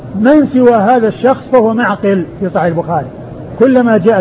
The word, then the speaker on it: معقل: من سواه في صحيح البخاري بهذه الصورة فهو معقل, معقل بالعين والقاف, بالعين الساكنة والقاف المكسورة.